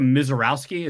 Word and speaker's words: Misiorowski: Um, this kid, Jacob Miz, I don't even know how to say his name. It's pitcher Jacob Misiorowski. Is that how you say it? – Yes.